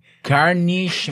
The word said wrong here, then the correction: Carnegie Mellon: Es Carnegie Mellon.